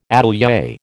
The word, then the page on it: atelier: American English